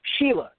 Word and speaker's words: Shelah: Sheila, just like the girl's name. – Sheila. Okay, Mike Sheila, S-H-E-L-A-H.